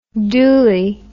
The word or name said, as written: dooley